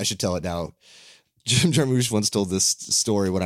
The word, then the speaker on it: Jarmusch: which I think it's pronounced Jim Jarmusch.